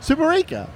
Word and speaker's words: Superica: Am I saying that right? Superica. – Well, Superica. – I'm not going to say you're saying it wrong, but it's not right. – All right. How do you say it? – Superica. – Superica. – Yeah. – That's what I'm saying. – Okay. Superica. – Superica. – Just Superica.